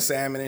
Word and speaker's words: salmon: Bitch be pronouncing the L and salmon and shit.